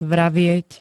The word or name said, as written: vravieť